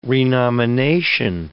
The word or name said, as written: renomination